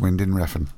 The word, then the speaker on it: Winding Refn: Is it like Winding Refn? – It's Winding Refn.